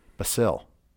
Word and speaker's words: Basile: I was gonna say, looking at his name, there's no T in it. So I'm going to go ahead and venture. You pronounced it wrong. – I did. Basile. – I don't speak French. So yeah, I'm thinking Bastille Day. That's what was in my head. – Yeah. – Basil. Basil.